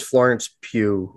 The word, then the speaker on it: Florence Pugh: You'd like it because Florence Pugh, or whatever you say your name's in it. – That is how you say her name.